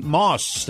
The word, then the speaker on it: Maus: And before you buy elsewhere, see Moss. That's spelled M-A-U-S.